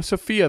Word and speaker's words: Sophia: I think people have most fun with the third-person Sophie sections, and I'm wondering if I'm saying it right or not. No, Sophia the third. So maybe it's Sophia. Sorry, Sophia. Blech.